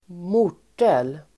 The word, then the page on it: mortel: [²m'o:r_tel]